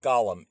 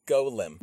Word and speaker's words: Golem: So, guys, don't pronounce it like Golem. It's Golem.